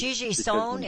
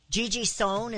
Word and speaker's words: Gigi Sohn: Is Gigi Sohn? Is that how you say her name? – I'm sorry? – Gigi Sohn? Is that how you say her name? – Yes, ma'am. Exactly. And Biden wants her to be FCC commissioner because when she is, she will punish shows like yours.